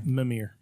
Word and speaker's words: Mimir: If Kratos is telling the story, if Mimir, is that how you say his name? – Mimir. – Mimir? It's going to be Mimir.